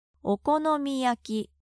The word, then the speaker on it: Okonomiyaki: Okonomiyaki. – Please repeat. – Okonomiyaki.